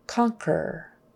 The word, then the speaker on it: conquer: But then we have words like conquer, conquer, U is silent, turquoise, turquoise, what a pretty turquoise ring you have.